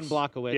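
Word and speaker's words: Błachowicz: The other one, I think I'm pronouncing right, Blachowicz. – Yeah, Jan Blachowicz.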